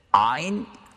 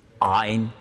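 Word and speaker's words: Ein (عين): Und in der Grammatik lernt man Ein, spricht man so aus mit Muskeln, die man im Deutschen nur braucht beim Erbrechen. Also ihr könnt zu Hause mal üben, nicht hier. Ein.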